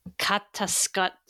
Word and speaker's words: Katatsukashi: It's a K, not a T. Katatsukashi.